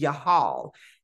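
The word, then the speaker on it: yahal (יחל): So when we look at the Hebrew breakdown, it's the word for travail in Hebrew is yahal, and it's spelled yud hai lamed.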